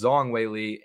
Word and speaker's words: Zhang Weili: I've been pronouncing it Zhang. But apparently it's Zhang Weili.